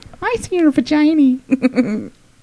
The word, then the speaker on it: vaginae: I've actually learned the proper pronunciation of the plural of vagina is vaginae. – Yeah. – I was saying vaginae or something like that. – Vagina, It's vaginae.